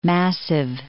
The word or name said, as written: Massive, Mount